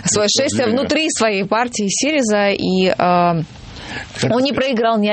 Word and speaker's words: Сириза: Я просто хочу сказать, что, ну, во-первых, нам подсказали наши специалисты по Греции, что правильно называется Сириза. – Сириза. – Сириза. – Да, Сириза. – Ну, вот. То есть на ударение на первом слоге.